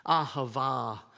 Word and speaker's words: ahava: This one, the Hebrew word which is pronounced ahava, means to give.